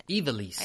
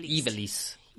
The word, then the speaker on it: Ivalice: Ivalice? How do you say it? – Ivalice. Ivalice. – Ivalice? – Mm-hmm. That's how I would say it. Ivalice.